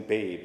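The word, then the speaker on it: Baab: Recently, I read an anecdote from Presbyterian minister Lynn, I believe it's pronounced Lynn Babe, it's B-A-A-B, that talks about this confusion.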